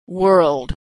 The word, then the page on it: world: American English